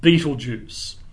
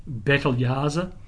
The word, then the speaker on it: Betelgeuse: And the star that we're looking for here is called Betelgeuse. Now, there are all sorts of different pronunciations for this, but none of them are correct according to the old Arabic pronunciation, something along the lines of Betelgeuse, which means armpit of the big man.